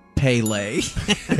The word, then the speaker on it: Pelé: Like, it's like the Chinese restaurant, except with an L. Pelé. – Yeah, he's not Pei-wei.